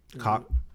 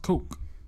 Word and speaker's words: Koch: Cock. – It's pronounced Koch.